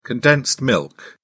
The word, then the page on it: condensed milk: British English